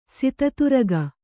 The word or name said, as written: ಸಿತತುರಗ